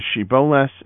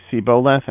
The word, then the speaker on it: Shibboleth: and apparently they were unable to pronounce the shin in Shiboleth. Instead, they would say Siboleth, and hence the English word Shibboleth.